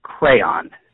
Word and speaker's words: Craiyon: So instead of Bard, you might want to look at something called Crayon. It's spelled with an I in it, C-R-A-I-Y-O-N.